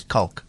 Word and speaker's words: Kalk: So just to give you an idea of why they call Cork Bay, or why Cork Bay got its name. Cork, or the proper pronunciation is Kalk.